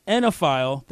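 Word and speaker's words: oenophile: He is a fantastic basketball writer and a tremendous enophile. That's how you pronounce that word when it means you like wine.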